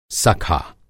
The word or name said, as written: सखा